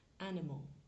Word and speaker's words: animal: Et le i et le a sont pas vraiment prononcés en fait, c'est des petits e. Animal.